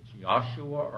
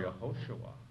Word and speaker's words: Yehoshua: If you look at the sixth book of the Bible, how do you pronounce Joshua in Hebrew? It's Yahshua or Yehoshua, not necessarily Yeshua.